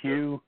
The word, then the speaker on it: Hugh: Yes, my name is Hugh, H-U-G-H. The last name, Trawlson, is spelled T like Tom, R-A-U-L, S like Sam, E like Edward, and like Nancy, and you can just put that on YouTube or Google it.